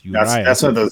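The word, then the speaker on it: Urías: There's an accent in that letter there, so it's probably pronounced Urias.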